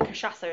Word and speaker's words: cachaça: It's that the cachaça is really coming through.